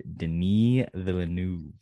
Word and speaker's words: Denis Villeneuve: From director Denis Villeneuve.